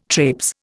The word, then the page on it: traipse: Indian